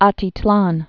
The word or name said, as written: Atitlan